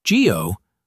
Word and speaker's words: GEO: GEO, pronounced like the GEO in geography, a private contractor.